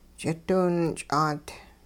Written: ch'idiwinch'aat

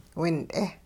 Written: wint'e

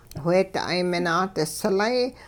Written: wheeda'ay minaa'dislay

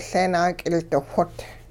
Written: Leenaak'ildixwot'

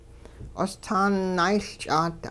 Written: qostaan naayLch'aat

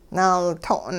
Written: naalto'n

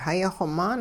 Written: hayixw-maan

